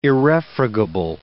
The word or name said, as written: irrefragable